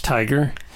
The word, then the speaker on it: TIGAR: It's T-I-G-A-R. It's not like the animal. It's TIGAR. but it's pronounced Tiger.